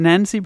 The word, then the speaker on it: Nenci: I, I think he pronounces it, it Nancy, but it's spelt N E N C I.